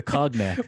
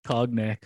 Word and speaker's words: cognac: No, the cognac, the cognac. – Why do you have to pronounce all the letters? – I'm American. Cognac. – It's cognac. You know it's cognac. – I know it is, but I'm American.